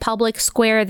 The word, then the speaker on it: Public Square: It is spelled Public SQ. You pronounce it Public Square.